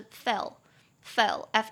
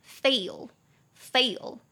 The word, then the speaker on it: fail: 我那个时候发的很像是 fell，fell，f e l l，很像是跌倒这个字的过去式 fell，但是真正那个失败 f a i l 的发音应该是 fail，fail。